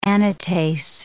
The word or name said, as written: anatase